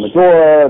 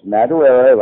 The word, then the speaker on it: Madureira: But it was always Madureira. Everyone called him Madura, and they couldn't figure it out. And I'm like, no, it's Madureira, like Botarera. You know, it's that's how you say it.